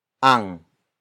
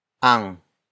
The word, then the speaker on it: ang: Ang. Ang. Ang is spelled as A-N-G.